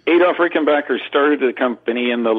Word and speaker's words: Rickenbacker: And then, you know, that's the way John Hall likes it pronounced. Because of the change in the spelling, that's the way it should be pronounced, Rickenbacker.